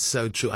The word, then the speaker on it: SoTru: And so true.